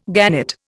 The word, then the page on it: gannet: Indian